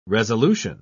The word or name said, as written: resolution